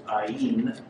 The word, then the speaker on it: Ayin: And I'm going to read this entire portion that we've been looking at here under the Hebrew letter Ayin. Some pronounce it Ayin.